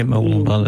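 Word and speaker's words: Mooloolaba: And we've got a show up in Mooloolamba, if I pronounce that correctly. – Yeah, Mooloolamba, that's right.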